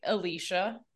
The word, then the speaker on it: Ileisha: Like, it's kind of like Alicia. Yeah. – Yeah, but there's like an extra syllable.